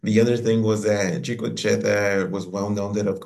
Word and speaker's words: Chico Che: The next song is, I can't say it right, Chico Che. Did I? – Chico Che, yes.